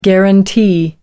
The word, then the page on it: guaranty: American English